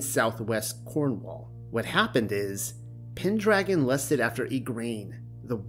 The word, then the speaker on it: Pendragon: He went by the name of Uther Pendragon, FYI. Mm hmm. It is pronounced pen, but then you have to follow the syllables through to dragon.